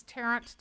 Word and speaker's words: Tarrant: My name is Tarrant, T-A-R-R-A-N-T, Blake.